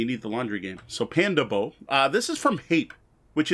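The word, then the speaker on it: Pandabo: The way I think this is pronounced is Panda Bo. It's all one word. It's Panda B-O, not as in body odor.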